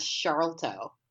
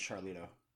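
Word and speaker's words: Sharlto: So the guy who plays Murdoch, is that how you say his name? Charlito? Because I'm reading it as Charlto. – No, it's Charlito.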